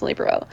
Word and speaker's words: libero: And I was like, that's how you say it is libero. And like, if someone said it differently, I was like, oh, that's wrong.